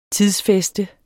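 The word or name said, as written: TIDSFÆSTER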